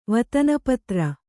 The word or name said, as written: ವತನಪತ್ರ